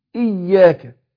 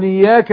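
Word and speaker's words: إياك: الاي، لا زي حرف الاي، اقول اي اياك. وبعدين الياء بعدها مشدده، ما ينفعش ان انا انطقها من غير تشديد، لان هيتغير المعنى، معنى الايه. اقول اياك نعبد واياك، كتير بنطقوها كده للاسف.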